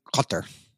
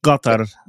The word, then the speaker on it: Qatar: The Arabic one is Qatar. Right. Okay. You live there, right? How did you say it? – Yeah. I mean, it's Qatar. Like Qatar. Gulfy Arabic is even a little more guttural.